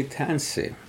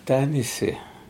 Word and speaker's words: Tansi: For example, when I say Tansi, our people say Tanise, Tanise. They don't say Tansi.